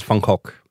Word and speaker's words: Van Gogh: So oh, so uh, the the actual uh, pronunciation is Funkok. Aha. Uh-huh. Well, I'm, I'm certainly not going to say that. – um, that's the Dutch way.